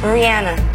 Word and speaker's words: Rihanna: Rihanna. I say Rihanna, but Rihanna is still okay. – So that's how she pronounces her name, Rihanna. We're saying it wrong, not Rihanna.